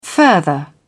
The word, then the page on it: further: British English